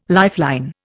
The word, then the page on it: lifeline: Indian